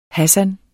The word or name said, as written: Hassan